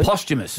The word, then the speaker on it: posthumous: Posthumous. – Yes. Yes. So that's how you say it, posthumous, it's spelt post-hummus, like if you read it.